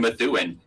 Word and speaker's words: Methuen: God, from Methuen too. And like some excellent pronunciation of Methuen. I feel like a lot of people don't nail that. That was really good.